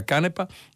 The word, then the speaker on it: Canepa: Eh, Camilla, molti ascoltatori, parecchi ascoltatori, e li ringrazio, mi hanno detto eh, Canepa anziché Canepa, io ho de- usato entrambe eh, i, le, le, pronunce, pare che quella giusta sia Canepa.